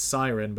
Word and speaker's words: Psyren: If you want to read it, the series is spelled, it's Siren, but it's spelled P-S-Y-R-E-N.